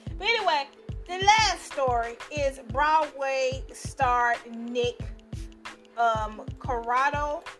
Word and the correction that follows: Cordero: Am I saying his name right? Cordero.